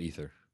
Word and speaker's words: Aether: They call it Aether. – Oh, is it Aether? – Yeah. – Sorry. – No. It's probably Aether, but they call it Aether. – Well, it's spelled A-E-T-H-E-R. So I was pronouncing...